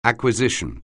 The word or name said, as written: acquisition